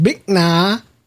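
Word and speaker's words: Bích Nga: and it's pronounced Bích Na.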